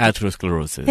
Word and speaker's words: atherosclerosis: the arterial sclerosis. How do I pronounce that, by the way, Dr.? Atherosclerosis.